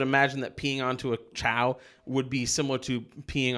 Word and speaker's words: Chao: I thought it was KO. – It's spelled CHAO, so And there's the Chaos Emeralds. So yeah. See, that's what I thought for the longest time, but at some point I heard them being referred to as Chao.